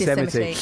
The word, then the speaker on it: Yosemite: It's spelt Yosmite, isn't it, that word? – My friend Rob used to say, oh, should we go to Yosemite? – Yeah, it's Yosemite. Yeah.